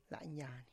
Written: lanyany

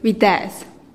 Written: biteez

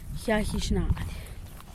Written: yagizhnad

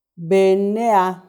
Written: benneha

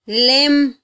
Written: lem

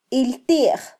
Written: iltij

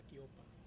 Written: kopa

